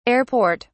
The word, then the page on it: air port: British English